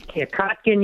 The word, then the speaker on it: Kotkin: Well, Professor Stephen Notkin, am I saying it right? – Yeah, Kotkin, you got it, John.